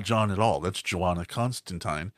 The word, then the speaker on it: Constantine: Like, we do have John Constantine, or Constantine, as it's properly pronounced.